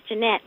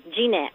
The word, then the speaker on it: Jeanette: It's pronounced Jeanette, but I'm fine, thank you. – Oh, so how is that? Is that with two T's and an E at the end? How does that work? – Yes, it's Jeanette. – Jeanette, but yes. I pronounce it Jeanette. – Oh, that's acceptable, just as long as you don't call me Janet.